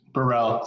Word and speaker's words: Burrell: Burrell. Close Burrell. Enough.